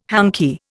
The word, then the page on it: hanky: Indian